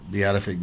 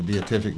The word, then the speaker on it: beatific: That's from Paul, and we call this the beatific vision or the beatific vision. I've heard it pronounced both ways by competent scholars or theologians.